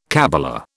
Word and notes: kabbala: British English

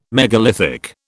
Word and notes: megalithic: British English

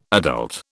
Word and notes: adult: British English

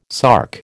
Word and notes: sark: American English

